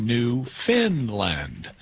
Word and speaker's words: Newfoundland: It's spelled Newfoundland, but it's pronounced Newfinland, even though it's not located in Scandinavia.